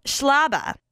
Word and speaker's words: Schlaba: It's Schlaba. Rhymes with Jabba the Hutt, which is why a guy in high school called me Schlaba the Hutt.